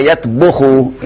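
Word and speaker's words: yatbuhu (يطبخ): Nah, ini memang sering kata tabakha ini sering salah pengucapan ya. Mungkin sudah terbiasa ya. Iya, bukan tabakha yatbahu. Tabakha yatbuhu. Yatbuh. Bukan yatbah.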